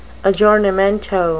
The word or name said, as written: aggiornamento